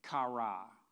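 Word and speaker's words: kara (קרא): The key word, singular word in the book of Jonah is a Hebrew word, kara, kof, resh, aleph. Aleph is sounds like an A, but it's actually a silent letter that usually carries an A.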